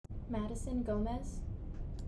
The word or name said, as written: Madison Gomez